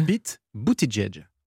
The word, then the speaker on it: Buttigieg: Comment vous le prononcez ? – Pete Buttigieg. – Vous êtes sûr ? – Oui, sûr et certain. – On ne dit pas 2 fois Edge ? – Non, parce que moi je regarde les late shows américains et j'ai vu les humoristes prononcer Pete Buttigieg.